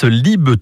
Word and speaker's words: Lieb: Et son nom s'écrit L-I-E-B, mais se prononce Lib, tout simplement Julien de son prénom, Julien Lib, qui chantait là autrement.